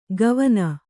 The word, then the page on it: ಗವನ: gavana